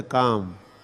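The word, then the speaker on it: calm: जैसे काम, काम का काम अंग्रेजी का जो शब्द है काम, उसमें क्या है, स्पेलिंग क्या है, सी ए एल है ना, एल तो कहते नहीं है, काम कहते, काम is very काम एंड क्वाइट हियर, काम मतलब शांत।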